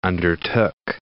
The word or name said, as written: undertook